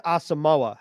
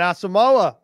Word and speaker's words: Asamoah: Oklahoma linebacker, and I'm going to try to pronounce his name properly, Brian Asamoah. How is that? – You've been practicing. – Okay, all right. Brian Asamoah, let's ride.